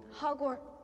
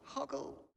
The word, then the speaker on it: Hoggle: Hogwart. – Hoggle? – Hoggle.